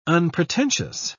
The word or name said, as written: unpretentious